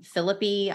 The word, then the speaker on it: Phillippe: Reese Witherspoon and Ryan Phillippe, I believe, is how you're supposed to pronounce his last name.